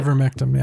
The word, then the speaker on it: ivermectin: What's it? Ivermectin? Am I saying that right? – Ivermectin, yeah.